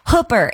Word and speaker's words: Hooper: The city of Hooper, it's spelled like Hooper, H-O-O-P-E-R, but it's pronounced similarly to Hooker.